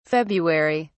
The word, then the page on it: february: British English